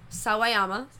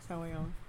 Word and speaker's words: Sawayama: I read it wrong. Sawayama. – Yeah. – Sawayama. I was like, nah, that's too many syllables. That's yeah. – wrong. – Yeah. – I'm so sorry. Sawayama.